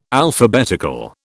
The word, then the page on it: Alphabetical: British English